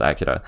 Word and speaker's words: Akira: Akira, well, I think it's correctly pronounced Akira. That's how I've been pronouncing it. I'm more than welcome to be wrong though. I did used to pronounce it Akira. But I think the correct pronunciation is Akira.